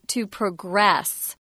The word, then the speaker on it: progress: When it's used as a verb, the pronunciation's different. It's to progress.